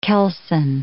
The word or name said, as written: keelson